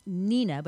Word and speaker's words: Hnina: And it's Nina, but it's spelled H-N-I-N-A.